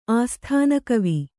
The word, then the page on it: ಆಸ್ಥಾನಕವಿ: āsthānakavi